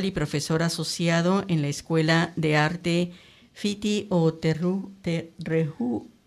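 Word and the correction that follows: Whiti o Rehua: ¿Cómo se dice? – Se dice Fiti o Rehua. – Sí. Fiti o Rehua. – Es el nombre maori de la escuela.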